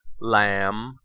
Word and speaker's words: lamb: The letter B is usually silent at the end of words in the combination MB, in words of Old English origin. Examples of these words include comb, crumb, dumb, lamb, numb, and womb.